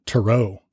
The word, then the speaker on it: tarot: Which a lot of traditional readers, which we are not, call them tarot cards with an emphasis on the ro.